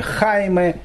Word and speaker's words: Хайме: а найдете его испанское произношение Хайме первый завоеватель.